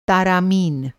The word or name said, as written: तारामीन